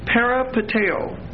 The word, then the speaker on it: peripateo: The Greek word for walked is peripateo. P-E-R-I-P-A-T-E-O. P-E-R-I-P-A-T-E-O. And that O is a long O.